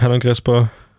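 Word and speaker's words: Hernán Crespo: Jetzt muss ich aufpassen, dass ich es richtig ausspreche. Hermann Crespo? Ja, wir sagen da Sehr cool. – Hermann, Hernan Crespo, ja, ja.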